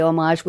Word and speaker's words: homage: He creates mantique shadow boxes that are an homage Mark, I say homage with a silent H because that's the way the French say it, homage. But those of you who don't know, it's spelled with an H, homage.